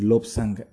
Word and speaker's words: Lobsang: Mi nombre es Lobsang Salguero, se escribe L-O, B larga, S-A-N-G, Salguero.